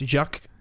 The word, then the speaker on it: Jacques: So Jacques Audiard, I apologize to you, Jacques, if I'm pronouncing your name wrong. – Again, when it's French, I don't think you put the S on the end, so it's just Jacques.